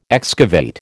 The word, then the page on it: excavate: American English